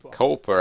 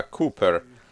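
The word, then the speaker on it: Cowper: Много лет назад у меня была привилегия побывать в английском городе Оуни и в доме Уильяма Купер, не Купер, а Купер. Вот как мы произносим это имя.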